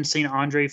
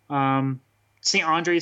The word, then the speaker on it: St. Andre: Look up my name, Nathan St. Andre Photography. St. Andre is spelled weird.